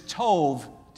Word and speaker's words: tov: Now that word good is tov, T-O-V is what we would spell it in the English alphabet. Tov, T-O-V.